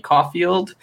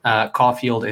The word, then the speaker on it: Caulfield: You can find me on Twitter and Twitch and pretty much anywhere else on the internet at Call Me Caulfield. Caulfield is spelled C-A-U-L-F-I-E-L-D.